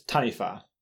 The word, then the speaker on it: taniwha: It's taniwha. – Taniwha. – Taniwha. T-A-N-I-W-H-A. So W-H is often pronounced, well, is pronounced as a wha in Māori.